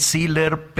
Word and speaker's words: Siller: Yo creo que al principio lo dije bien, Siller, pero pues no sé si hay que castellanizarlo, Gaby. Tú dinos cómo lo decimos correctamente. – ¿Cómo estás? Muy buenos días. Es siller, así como se escucha tal cual, pero no hay problema.